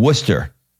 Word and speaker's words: Worcester: I think I'm going to say this right, in Worcester. Did I say that right? – Ooh, you nailed it.